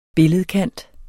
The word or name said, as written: billedkant